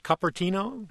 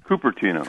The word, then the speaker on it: Cupertino: And our winner, as always, randomly selected from the correct answers, is from Cupertino, California. Are you there? – Yes, I am. – Did I say that correctly? Is it Cupertino? – Cupertino.